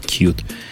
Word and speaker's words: Qt: Тебе бы букучит, что Qt читается как cute.